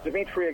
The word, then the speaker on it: Dimitri: Dimitri spelled with three I's, D-I-M-I-T-R-I, 2020LLC. That's Twitter. Facebook, it's Dimitri again with three I's, D-I-M-I-T-R-I.